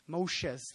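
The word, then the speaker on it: Moshes (משה): Moshes, that's how you say it in Hebrew.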